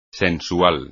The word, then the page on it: sensual: espagnol